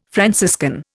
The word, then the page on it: Franciscan: Indian